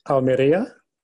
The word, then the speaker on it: Almeria: Okay, so Murcia, Almeria, and Granada Okay, forgive my Spanish, pronunciation. You can correct me. – So Murcia. – Thank you. – Almeria. – Yeah. – And Granada.